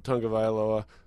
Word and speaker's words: Tagovailoa: I like what he does with this program, his leadership, this offensive unit led by um, Tagovailoa did I say that right? Yep.